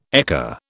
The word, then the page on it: ekka: British English